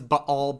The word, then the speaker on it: Baal: So, I mean, the proper way to say is Baal, but that gets obnoxious after a little while, and so we'll say Baal. And Baal Zavuv instead of Baal Zebub or Beelzebub or whatever.